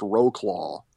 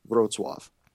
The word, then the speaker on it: Wrocław: I didn't really see much of Poland other than Wrocław, which looks like it should be pronounced Roclaw in English. It's okay. W-R-O-C-L with a slash through it, A-W. – Okay. – But yeah, that's Wrocław. It was just really cool.